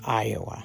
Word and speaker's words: Iowa: My first name is Iowa. Yes, it is pronounced like the state.